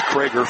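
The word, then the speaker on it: Creager: Jacob Krager, spelled C-R-E-A, pronounced Krager, 5'10 sophomore.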